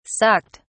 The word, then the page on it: sucked: British English